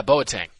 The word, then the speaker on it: Boateng: Boateng? – Yeah, Boateng.